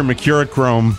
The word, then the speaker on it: Mercurochrome: So get your Mercurochrome. Did I say that right? – Yes.